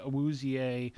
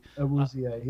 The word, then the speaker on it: Awuzie: He hits Skowronek in the hands, bounces up in the air, and it's intercepted by Awuzie. – Awuzie, yep.